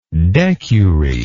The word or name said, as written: decury